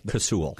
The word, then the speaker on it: Casull: Casull.